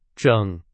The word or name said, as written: 整